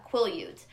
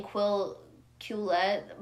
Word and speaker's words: Quileute: She was not of the, someone had corrected me on how I pronounced, Quileute. Is it Quileute? Yeah, Quileute. Cause I keep saying Quillette by accident.